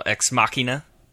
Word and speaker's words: Ex Machina: Yeah, and then some people say like Ex Machina.